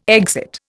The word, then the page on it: exit: Indian